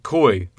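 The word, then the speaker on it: Coyle: So, we watched 2001's Hardball from 2001, directed by Brian Robbins, written by John Gattins, and based on the book by Daniel Goy. I think it's Goy. I'm not sure how to pronounce it. Coy, not Goy. I think it's Coy.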